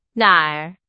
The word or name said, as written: 哪儿